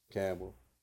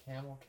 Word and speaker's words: Campbell: Campbell. – Okay. Have you noticed that some people say Camel County instead of Campbell? – Yeah. – Okay. Yeah, I noticed that too. You're the first... You say Campbell, though. – Yeah. Well, it's C-A-M-P-B-E-L-L, you know.